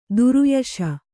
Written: ದುರುಯಶ

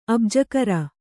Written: ಅಬ್ಜಾಕರ

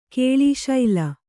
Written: ಕೇಳೀಶೈಲ

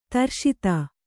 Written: ದೃಷದ

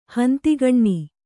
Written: ಹಂತಿಗಣ್ಣಿ

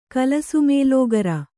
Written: ಕಲಸುಮೇಲೋಗರ

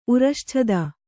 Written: ಉರಶ್ಛದ

